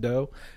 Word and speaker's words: Deau: So I'm pretty sure that would be pronounced as just dough, but that sounds good.